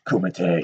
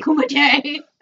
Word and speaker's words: kumite: Yeah, Kung Fu, Jean-Claude Van Damme, the Kimute. I gotta say it wrong. – Kumite. – Kumite.